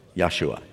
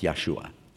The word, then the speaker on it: Yeshua: How do you say the name Jesus in Hebrew? Yeshua, Joshua. We say in English, Joshua, it's Yeshua.